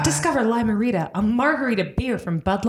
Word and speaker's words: Lime-A-Rita: It's Limerita, and it's spelled like this. Lime-A-Rita.